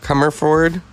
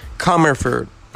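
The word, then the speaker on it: Commerford: Tim Comerfield. And, or did I say that right? Sorry. I always mess that up. We're going to do this properly. Brad Wilk. Tim Comerford. – Comerford. – Okay, thank you.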